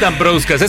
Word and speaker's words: Dambrauskas: Según yo es Dambrauskas. Es que es lituano.